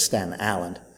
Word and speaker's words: Staten Island: I mean, I never believed for a minute that we got out here in Surgeonsville, New Jersey, from Staten Island, New York. You got to pronounce it correctly. It's Staten Island.